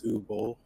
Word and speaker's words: Uwe Boll: So we all know, I think his name is pronounced Uwe Boll.